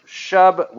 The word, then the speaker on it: shub: If we deal with those two words, the term shub would show up first and most often. And it generally is spelled, again, we're working from, translated from the Greek, S-H-U-B, to shub, or in this case, to repent means to turn, to return, to seek, or to restore.